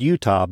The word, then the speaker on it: Eutaw: It's pronounced Utah, but it's spelled E-U-T-A-W.